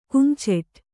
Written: ಕುಮ್ಚೆಟ್

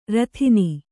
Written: ರೋದನಿ

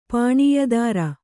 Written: ಪಾಣಿಯದಾರ

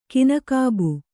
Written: ಕಿನಕಾಬು

